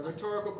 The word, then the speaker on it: rhetorical: It was a rhetorical question. I forgot what syllable should the emphasis be. Sorry, yes, a rhetorical question.